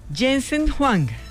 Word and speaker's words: Jensen Huang: Mire, Jensen Huang, ¿lo dije bien, Fernando Quijano? – Sí, sí, bien.